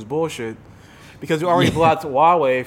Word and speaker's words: Huawei: Yeah, well, it's spelled like HUA. – Yeah, yeah, but it's Huawei.